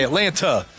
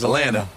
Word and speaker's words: Atlanta: Well, some people say Atlanta wrong. They say Atlanta. If you're from Atlanta, there's no T. It's Atlanta. – Atlanta. – Yeah. Like two N's. Atlanta. Atlanta.